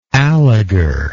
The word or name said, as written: alegar